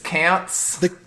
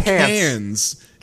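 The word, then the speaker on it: Quinze: Okay, how do they pronounce Quince in the show? Because they call him like... – Cants? – They say call him Cans. They say Cans. – Like the film like festival. – C-A-N-S.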